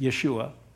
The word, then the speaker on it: Yeshua: But Yeshua, or some people pronounce it Yeshua, comes from the same root word where we get the name Joshua.